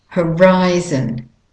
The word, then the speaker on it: horizon: And the last word, horizon. Horizon. And the ri is the stressed, main stressed syllable. The o is a schwa, a de-stressed. Horizon. And the second, both o's are de-stressed. They're schwa vowels.